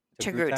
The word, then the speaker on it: Togruta: I think it's Togruta. – Togruta.